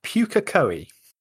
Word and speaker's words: Pukekohe: You are on about Pukekohe. – Is that how you say it? – Yes.